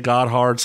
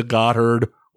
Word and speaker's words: Gotthard: And it's depending on how you say it. Some say Gotthard. Some say Gotthard. Whatever. Gotthard.